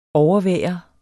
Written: overvære

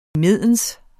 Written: imens

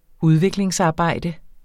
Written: udviklingsarbejde

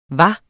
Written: hvad

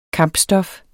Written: kampstof

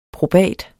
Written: probat

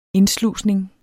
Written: indslusning

